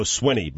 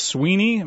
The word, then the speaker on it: Swinney: It's Dabo Swinney, by the way. – Yeah. I've heard it pronounced Sweeney, but there's definitely an I double N E Y there. – How is it spelled? – S-W-I-N-N-E-Y.